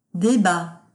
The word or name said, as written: debat